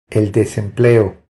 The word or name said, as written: El desempleo